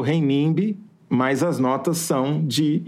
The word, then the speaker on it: renminbi: ele realizou a primeira transação comercial direta em renminbi, que é a moeda chinesa. Né? Fala de novo o nome da moeda chinesa. Renminbi, ou renminbi, ou renminbi. Pode ter as duas pronúncias.